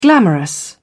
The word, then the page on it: glamorous: British English